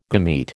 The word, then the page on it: gamete: American English